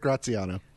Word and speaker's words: Graziano: Yeah, it's Derek Graziano.